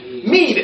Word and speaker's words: mead: Mead, M-E-A-D. Mead. Okay, it's the old times they used to pronounce it med, but I think mead is more common nowadays.